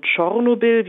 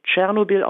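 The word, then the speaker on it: Tschornobyl: Das ist einmal die Atomkatastrophe von Tschernobyl, wie es auf Ukrainisch heißt, bei uns wird es ja meistens russisch Tschernobyl ausgesprochen.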